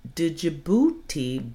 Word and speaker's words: Djibouti: And people, you pronounce it Djibouti, beauty, beauty.